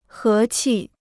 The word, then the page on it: 和气: hé qi